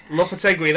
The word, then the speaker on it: Lopetegui: The manager, Julen, I mean, I'm going to have to get my pronunciation right here. Lopetegui, there we go, that's the one.